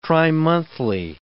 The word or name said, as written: trimonthly